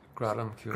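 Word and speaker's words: Gradam Ceoil: Is it the Gradam Kjol? – Gradam Kjol. – Gradam Kjol, sorry.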